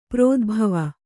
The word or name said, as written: ಪ್ರೋದ್ಭವ